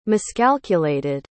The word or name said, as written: miscalculated